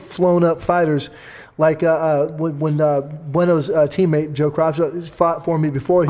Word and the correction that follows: Cropscott: Cropscott. He gets mad when you say it wrong.